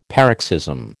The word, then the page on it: paroxysm: American English